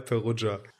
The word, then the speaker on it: Perugia: Ja, Perugia.